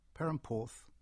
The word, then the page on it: Perranporth: English